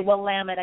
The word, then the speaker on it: Willamette: Willamette? – Willamette. – Willamette, yes. Willamette.